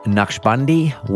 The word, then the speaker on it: Naqshbandi: Masood Naqshbandi, welcome to The Mentor, mate.